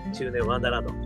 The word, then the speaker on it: 中年ワンダーランド: 中年不思議国と書いて中年ワンダーランド、こちらの方ですね、ぜひぜひ検索していただくと元気が出る曲が聴けますよ。